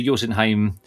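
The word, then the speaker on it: Jötunheim: And following the destruction of the bridge to Jotunheim during the fight with Baldur, Kratos and Atreus, we need to find effectively an alternative route into the realm of the giants. – Lovely, lovely pronunciation of Jotunheim there.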